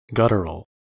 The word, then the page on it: guttural: American English